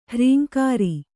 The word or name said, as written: ಹ್ರೀಂಕಾರಿ